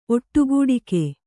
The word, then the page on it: ಒಟ್ಟುಗೂಡಿಕೆ: Kannada